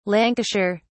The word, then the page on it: lancashire: British English